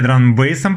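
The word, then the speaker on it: драм-н-бейс: А, так вот, э, завязали знакомство, увлеклись дабстепом, драм-н-басом, точнее драм-бейсом, правильно говорить.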